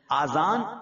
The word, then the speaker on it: اذان: بعض لوگ اذان کہتے ہیں, یاد رہے پہلے اذان کہتے کس طرح ہے, اس کے تلفظ سے بج لیں. اذان اذن کی جمع, اذن کہتے کان کو, یہ اذان نہیں ہے, یہ لفظ اذان ہے, ٹھیک ہے.